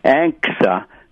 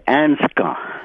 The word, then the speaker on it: ANCSA: But I always be a little disturbed about some of the things that relates to Alaska Native Claims Settlement Act, which is ANCSA, not ANSCA.